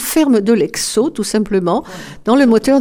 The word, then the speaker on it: Hecso: L'e-mail, ça serait l'e-mail de la ferme de l'Exo, comme ça se prononce, ferme de l'Exo.